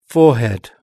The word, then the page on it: forehead: British English